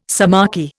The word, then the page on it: sciamachy: Indian